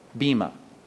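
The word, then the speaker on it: Bema (βῆμα): Let's go to 2 Corinthians 5, but it says in verse 10, we must all appear before, here's our word, Bema. Those next two words, judgment seat, translates one Greek word, B, long E-M-A, Bema.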